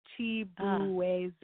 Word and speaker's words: Chibueze: It's literally, if you do it phonetically, it's Chibueze. So that's how it kind of sounds. Oh, yeah, it is, isn't it? Thank you. Chibueze.